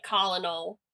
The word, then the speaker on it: colonel: But I did also really like Colonel Mustard, I think because I delighted in the fact that even though it is spelled like colonel, it was pronounced colonel.